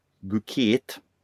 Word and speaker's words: Bucket: Nej, men det är ju en brittisk skådespelerska som vi känner igen som Harriet Bucket, eller Bucket som hon egentligen vill att det ska heta.